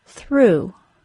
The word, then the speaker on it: through: Let's move on to the word through. Through, T-H-R-O-U-G-H, is pronounced the same as through, T-H-R-E-W. Through, T-H-R-E-W, is phonetic. We expect that the letters E-W can be pronounced as the O-O sound. Other words spelled E-W and pronounced as the O-O sound are the words chew, drew, and flew. So, if you can remember that through, T-H-R-O-U-G-H, and through, T-H-R-E-W, are pronounced the same, you should have less trouble with that word.